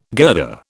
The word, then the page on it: girder: British English